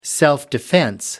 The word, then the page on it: self-defense: British English